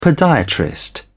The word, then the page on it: podiatrist: British English